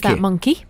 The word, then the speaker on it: Monki: Yeah, I'm loving this monkey dress. It's spelled M-O-N-K-I, isn't it? – Yeah, and I think you would pronounce monkey. Monkey.